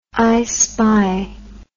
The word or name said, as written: i spy